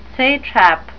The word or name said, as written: satrap